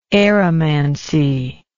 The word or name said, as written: aeromancy